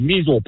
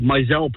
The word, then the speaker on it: Meisel: M-E-I-S-E-L. It's called Mizell Pond. The people call it Measle Pond.